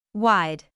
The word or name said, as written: wide